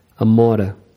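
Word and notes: amora: Brazilian Portuguese

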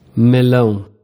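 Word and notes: melao: Brazilian Portuguese

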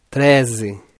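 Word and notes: 13: Brazilian Portuguese

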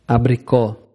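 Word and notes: abrico: Brazilian Portuguese

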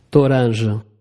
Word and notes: toranja: Portugués Br

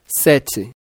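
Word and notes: 7: Brazilian Portuguese

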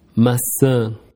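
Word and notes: maca: Brazilian Portuguese

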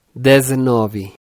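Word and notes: 19: Brazilian Portuguese

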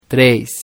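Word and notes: 3: Brazilian Portuguese

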